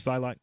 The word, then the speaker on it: Psylocke: Psylocke. Is that how you pronounce it? Psylocke.